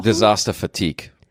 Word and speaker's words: Disaster Fatigue: Das habe ich jetzt bestimmt ganz falsch ausgesprochen. – Disaster Fatigue. – Ja, Heißt das so? – Es ist so, ja, also. – Und ich sage Fatigue, weil Fatigue ist Französisch und das heißt das Gleiche.